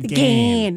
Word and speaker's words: Ghan: Oh, you probably said it properly, but we're Australian, so we call it the GAN.